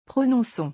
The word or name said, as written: prononcons